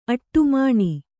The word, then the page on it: ಅಡಿಮಣೆ: aḍimaṇe